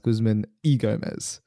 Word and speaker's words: Guzman Y Gomez: I learned that Guzman Y. Gomez is pronounced Guzman E. Gomez. – Yeah, this is Spanish pronunciation, bro.